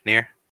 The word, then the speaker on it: Near: Yeah, Near. – So, Viz Media is releasing 2 books. Ow, I just hit my headphones. That was loud in my ear. 2 short story books in the Near universe, sort of.